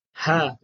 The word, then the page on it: h: Sanskrit